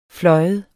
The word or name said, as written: flyve